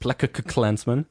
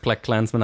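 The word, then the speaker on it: BlacKkKlansman: Er ist Plak Clansman, aber es sind halt, sie wollten 3K im Titel haben, weil es um den Ku Klux Klan geht.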